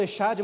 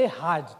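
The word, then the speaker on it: Errad (אחד): echad mas a pronúncia é Errad, tá bom? Eu estou lendo para ficar mais fácil.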